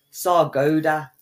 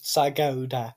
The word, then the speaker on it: Sargodha: That's how it's pronounced. Sargodha. – It says Sargodha. – It's Sargod. – It's got an A on the end, you dickhead.